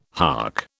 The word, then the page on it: hark: British English